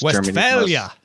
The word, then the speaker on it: Westphalia: Westphalia.